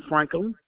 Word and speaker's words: Franklin: Kara Ann Franklin. – All right, could you spell that last name, please? – F-R-A-N-K-L-I-N.